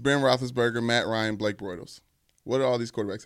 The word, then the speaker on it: Bortles: Bortles.